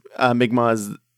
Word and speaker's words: Mi'kmaq: Is that how you say it? I thought it was Mi'kmaq, but okay. – I think it's Mi'kmaq is correct, I'm pretty sure.